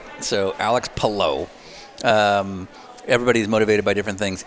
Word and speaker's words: Palou: Is it Palou or Palou? – Palou. – It is Palou. – It is Palou. Yeah. Take that, Townsend.